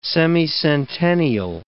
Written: semicentennial

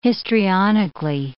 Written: histrionically

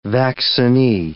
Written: vaccinee

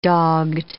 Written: dogged

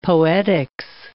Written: poetics

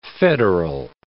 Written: federal

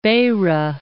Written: Beira

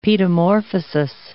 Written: paedomorphosis

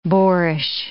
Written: boarish

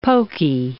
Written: pokey

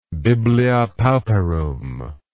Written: biblia pauperum

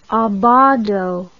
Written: abbado

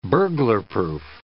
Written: burglarproof

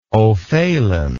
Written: ofaolain

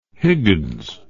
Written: Higgins

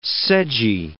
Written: sedgy

